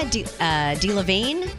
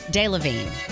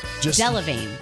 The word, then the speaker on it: Delevingne: Cara Delevingne is, did I say that right? – I don't think so. – Delevingne. Delevingne.